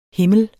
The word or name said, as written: HIMLE